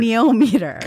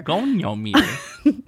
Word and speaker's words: goniometer: And how do you pronounce it? – I'm not looking at the word. Goniometer. – Goniometer. – Goniometer. – Goniometer. That's it.